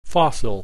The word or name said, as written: fossil